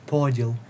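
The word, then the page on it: поділ: Ukrainian; podìl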